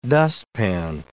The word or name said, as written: dustpan